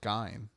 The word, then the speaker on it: Gein: It's actually pronounced Gein. – Gein? – No, I'm serious. Technically, it's Gein, but people say Gein.